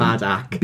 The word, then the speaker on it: Badac: B-A-D-A-C. – Like, just if you, a T short of Bad. – That's exactly Badak. What, yeah. I always wondered if it was meant to be pronounced with a soft C and it was Badass Theatre.